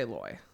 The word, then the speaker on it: lawyer: How do you pronounce the first syllable of L-A-W-Y-E-R? Lawyer. I say loy. So boy. Lar would be the South, though.